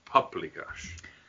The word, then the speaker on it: paprikash: Yes, as Deb pronounces it, paprikash. There's not an L.